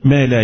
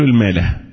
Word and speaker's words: ما لا: يقول سبحانه وتعالى أيشركون، شوف هنا يشركون خلص لف لسه ما تمش برغم من نهاية آية، أيشركون ما لا يخلق. البعض يقول الماله، المالة دي الضيعة، مفيش حاجة اسمها المالة، ما لا كيف تنطق مرة ثانية ما لا وليست المالة، يبقى ضيع المعنى من الميل بقى.